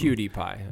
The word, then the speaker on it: PewDiePie: It's spelled P-E-W-D-I-E-P-I-E. PewDiePie. – Like CutiePie. – I think so. Or PewDiePie. – No, you don't say CutiePie.